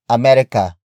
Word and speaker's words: Amerika: America is spelled as capital A, M, E, R, I, K, A. It is the Tagalog spelling of America, which Filipinos generally refer to the United States of America. After that, we have kayo. Kayo. Kayo. Kayo is spelled as K-A-Y-O.